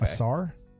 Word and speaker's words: Ausar: Asar, if I'm saying it right. Okay. A-U-S-A-R. – Okay. – Asar.